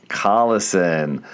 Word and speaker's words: Collison: Is that how you say it? – No, that's not how to say it. – How do you say it? – Collison.